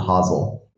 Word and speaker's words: Hasl: You nailed the pronunciation with the soft S to the Hasl.